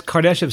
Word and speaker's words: Kardashev: So All right, and that's Kardashev scale, right? – Yes.